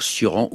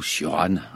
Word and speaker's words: Cioran: Alors, Cioran ou Cioran, on peut le prononcer des deux manières.